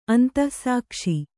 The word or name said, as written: ಅಂತಃಸಾಕ್ಷಿ